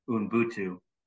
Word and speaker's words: Ubuntu: It's actually pronounced Ubuntu.